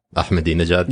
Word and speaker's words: Ahmadinejad: Ahmadinejad? – Yeah. Thank you for pronouncing that properly.